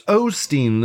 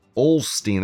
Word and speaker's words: Osteen: It's Osteen. It's O-S-T-E-E-N, not Olstein. There's no L in that name.